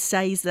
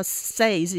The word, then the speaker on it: says: So one that comes to mind, similar to what Scott's talking about, is how Kim in Kath and Kim says, everyone says that, because that's how says is spelt, yes. – But we say says.